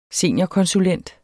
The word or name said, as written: seniorkonsulent